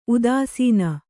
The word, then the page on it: ಉದಾಸೀನ: udāsīna